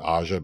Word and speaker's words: Aja: And then we learned that really it should have been Asia, but people kept pronouncing it Aja because of her incorrect spelling, so she just kept it.